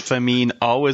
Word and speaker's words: Firmino: especially commentators keep saying Firmino. – I know, it's just Firmino, isn't it? – Yes, it is.